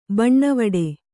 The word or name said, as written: ಬನಾವಟಿ